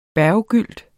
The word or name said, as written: berggylt